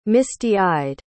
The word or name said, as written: misty-eyed